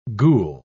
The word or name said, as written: ghoul